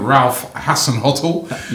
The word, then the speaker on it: Hasenhüttl: Now, you know, first game for, let me see if I pronounce it right again, Ralf Hasenhüttl.